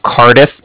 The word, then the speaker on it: Cardiff: And then the Bank of Cardiff is spelled C-A-R-D as in dog, I-F-F as in Frank Frank. So it's like car and different. – Respell that? – Not a problem. I know it sounds funny over the phone. Kind of hard to hear, too. But it's car diff. So it's C-A-R-D as in dog, I-F-F.